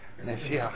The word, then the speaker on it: Mashiach: And of course, ever so often today, some people have taken a little Hebrew, will give it a Hebrew pronunciation, and wonder, well, in the world are they talking to? Mashiach.